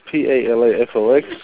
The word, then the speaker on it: Palafox: PALAFOX.